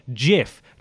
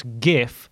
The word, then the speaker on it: GIF: According to him, the acronym is pronounced with a soft G, GIF, not GIF.